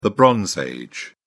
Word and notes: bronze age: British English

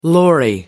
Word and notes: lorry: American English

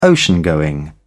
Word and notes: ocean going: British English

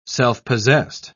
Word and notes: self-possessed: British English

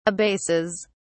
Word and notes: abases: British English

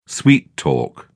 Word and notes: sweet-talk: British English

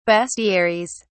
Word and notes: bestiaries: British English

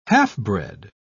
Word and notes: half-bred: British English

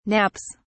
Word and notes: nappies: British English

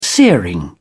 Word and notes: searing: British English

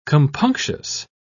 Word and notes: compunctious: British English

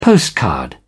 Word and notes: postcard: British English

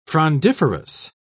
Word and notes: frondiferous: British English

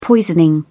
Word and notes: poisoning: British English

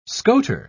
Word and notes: scoter: British English

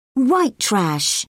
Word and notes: white trash: British English

